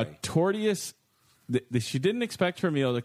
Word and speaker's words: tortious: When a woman ordered lasagna at a restaurant in the suburbs, she didn't expect her meal to come with a side of tortuous conduct. Am I saying it wrong? – Torturous? – There's no other R. T-O-R-T-I-O-U-S. – Tortious conduct? – Tortious?